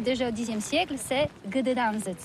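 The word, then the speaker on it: Gdańsk: C'est pourquoi on dit Gdansk.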